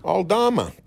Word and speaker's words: Aldama: Aldama.